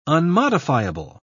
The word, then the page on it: unmodifiable: British English